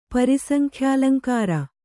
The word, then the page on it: ಪರಿಸಂಖ್ಯಾಲಂಕಾರ: pari saŋkhyālaŋkāra